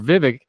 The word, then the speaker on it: Vivek: Plus, remember, Vivek rhymes with fake, is in this race, and everybody that's polling for him is going to vote for Trump as well.